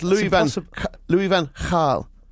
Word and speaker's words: Louis van Gaal: But the BBC themselves have got an article on the website which says it's actually pronounced, just to prove we're both wrong, like a KH, like in Lock in Scotland. So it's Louis Van Gaal.